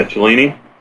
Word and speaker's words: Cellini: Yeah, Cellini.